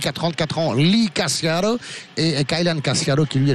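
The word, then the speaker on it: Casciaro: En défense, il y aura Ryan Cassiaro, c'est comme ça qu'il faut prononcer, ou Cassiaro, effectivement, à l'italienne, puisqu'ils ont des origines.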